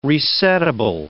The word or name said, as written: resettable